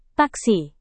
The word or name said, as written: taksi